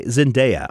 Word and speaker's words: Zendaya: My number five is XS, played by Zendaya, I think is how you say her name.